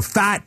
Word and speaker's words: Pfaadt: So that's why, uh, you know, Pfaadt is not going to be a guy uh, that's going to come up if I'm pronouncing his name the right way. You are, so no, you are.